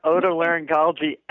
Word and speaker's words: otolaryngology: And she got otolaryngology absolutely correct.